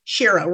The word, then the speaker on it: Shiro: Is it Shiro or Shiro? – Shiro rhymes with hero.